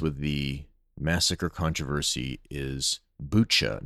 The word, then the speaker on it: Bucha: I've been saying Buka, and as typical, people probably know I pronounce names and places and words that are foreign to me pretty poorly, and I'll probably forget and pronounce it Buka again later, but just so you know, it's Bucha.